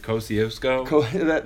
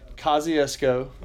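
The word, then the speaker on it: Kosciusko: I always pronounce it Kosciuszko. – That's not quite there. – I've been trying. – Yeah, Kosciuszko. – Okay. – The easiest thing, if you want to say Kosci, like the K-O-S-Y, which there is no Y in there, but if you want to say Kosci or K-O, you will not miss a beat, I know. But yeah, Kosciuszko, it's very hard to spell and very hard to pronounce.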